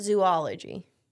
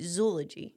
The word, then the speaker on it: zoology: So it's not zoology. – Zoology. – No. – Really? – Well... It made me nervous, but I'm pretty sure it's supposed to rhyme with eulogy, so it's supposed to be zoology.